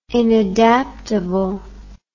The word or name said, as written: inadaptable